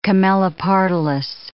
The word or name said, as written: Camelopardalis